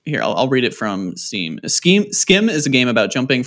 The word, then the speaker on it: Schim: Is it pronounced Skim? – Oh, S-C-H-I-M? – Yeah, and I don't even really know how to explain this game, but, like, you kind of have to jump.